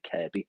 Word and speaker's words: Kirkby: That's K-I-R-K-B-Y. You might pronounce it Kirkby, but it's like a silent K, Kirby.